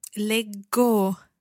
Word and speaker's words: leggo: Leggo. – So a double G sì. – Followed by the O, giving us a hard G sound.